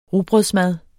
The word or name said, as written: rugbrødsmad